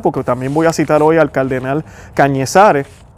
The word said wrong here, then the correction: Cañizares: eh, si no estoy pronunciando el nombre mal, Cardenal Cañizares, disculpen, Cardenal Cañizares, que también habla sobre esto.